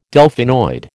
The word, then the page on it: Delphinoid: American English